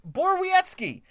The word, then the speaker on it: Borowiecki: Mark Borowiecki of Ottawa. – Is that how you say it? – Yeah.